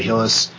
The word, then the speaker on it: Hillis: If you wanted to follow me on Twitter for some reason, you could follow me at Jedi Hillis. It's just Jedi, and my last name is spelled H-I-L-L-I-S.